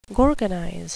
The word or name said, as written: gorgonize